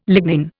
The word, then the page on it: lignin: Indian